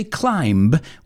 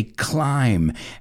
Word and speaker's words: climb: And climbers, I want to look at that word, because climb is a silent B. We don't say climb, we say climb.